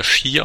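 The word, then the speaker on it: Shi (四): Es bedeutet ja Shi ausgesprochen, bedeutet Tod.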